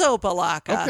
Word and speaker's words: Opa-locka: It is Opa-locka.